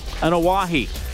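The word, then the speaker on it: Anawahi (Anoaʻi): Joe Anawahi. Did I say it right? – Anawahi.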